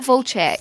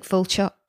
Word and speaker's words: Volchok: How are you saying that? Volchok, Volchek, Volch. Because it's spelt Volchek, but they say Volchek. – Yeah, I always say Volchek when I'm remembering it, but they do seem to say like Volchok.